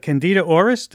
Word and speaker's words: Candida auris: Candida auris, did I pronounce that correctly? – Yeah,